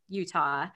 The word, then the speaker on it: Utah: And then when you went to University of Utah, did I say that right? – Yep, you're good.